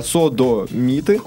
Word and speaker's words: содомиты: Значит, делится на слога «содомиты», то есть на удар, ударение на последний слог, это существительное, э, причем одушевленное.